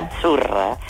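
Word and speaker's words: Azzurra: La Z no es la Z como decimos en catalán tan sonora, no. – Ah, es Achurra. – Muy bonito. – Es como pizza.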